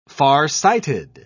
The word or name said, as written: farsighted